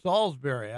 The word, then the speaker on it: Salisbury: Well, we pronounce it Salisbury down here in the South, but yeah. – Okay. All right. – I got to be with you. – All right. Okay. Salisbury.